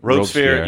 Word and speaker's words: Robespierre: Was it Robichaud or what? How do you say the guy's name? – Robespierre.